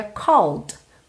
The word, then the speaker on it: called: porque você já sabe que esse é do called não é pronunciado. Logo, você teria called, called fechando nesse D, que é consoante oclusiva, pode aparecer mais ou menos.